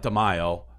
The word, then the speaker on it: D'Amelio: D'Amelio.